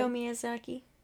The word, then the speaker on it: Hayao Miyazaki: Hayao Miyazaki.